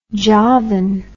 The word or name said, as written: javan